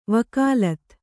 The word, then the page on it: ವಿಗಲಿತ: vigalita